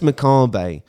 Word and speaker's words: macabre: Macabre, which is actually how you pronounce it, because I got ripped into for pronouncing it wrong.